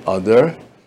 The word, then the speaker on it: Adar: And in the Jewish month of Adar, or in Israelis pronounce it Adar, we have the big Jewish festival called Purim.